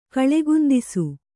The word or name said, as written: ಕಳೆಗುಂದಿಸು